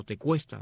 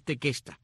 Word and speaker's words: Tequesta: Otros indios de esta área son los llamados tequesta o tecuesta, de acuerdo como lo mencionas, lo pronuncias al inglés o el español, pero es tequesta, tequesta con Q, T-E-Q-U-E-S-T-A,